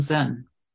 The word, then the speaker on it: Zen: Chan and Zen. Chan means meditation. It's the Chinese transliteration of the Sanskrit dhyana and is pronounced Zen in Japanese, San in Korean, and Tien in Vietnamese.